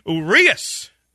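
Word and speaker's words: Urías: Urias is how it's pronounced.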